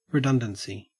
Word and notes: redundancy: Australian English

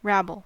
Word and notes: rabble: American English

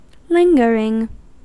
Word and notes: lingering: American English

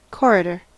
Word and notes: corridor: American English

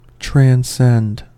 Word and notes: transcend: American English

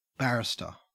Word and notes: barrister: Australian English

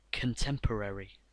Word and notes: contemporary: American English

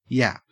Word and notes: yap: Australian English

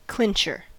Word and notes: clincher: American English

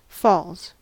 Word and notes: falls: American English

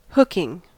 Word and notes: hooking: American English

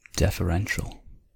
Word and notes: deferential: American English

